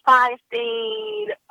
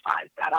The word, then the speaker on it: Feinstein: Rachel Feinstein. It's Rachel Feinstein. I always say it wrong.